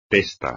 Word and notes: testa: espagnol

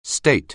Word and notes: state: anglais

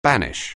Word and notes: banish: anglais

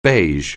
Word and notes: beige: anglais

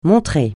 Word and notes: montrer: francais

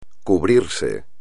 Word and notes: cubrirse: espagnol

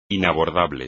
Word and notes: inabordable: espagnol

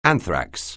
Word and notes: anthrax: anglais

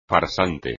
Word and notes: farsante: espagnol

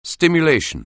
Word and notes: stimulation: anglais

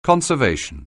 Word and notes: conservation: anglais

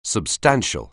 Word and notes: substantial: anglais

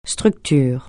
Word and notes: structure: francais

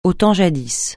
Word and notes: au temps jadis: francais

